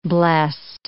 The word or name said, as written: blessed